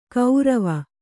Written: ಗೌರವ

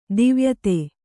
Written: ದೈವಾಯತ್ತ

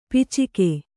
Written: ಪೈಚುಕೆ

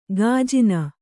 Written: ಗಜನಿ

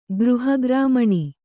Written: ಗೃಹಗ್ರಾಮಣಿ

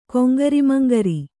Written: ಕೊಂಗರಿಮಂಗರಿ

